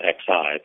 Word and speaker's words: Xi: President Xi, it's spelled X-I, but pronounced like Xi,